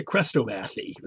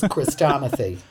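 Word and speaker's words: chrestomathy: I always pronounced a Crestomathy as a kid, but whatever. – It's Christomathy. This is a word I rarely, if ever, have used.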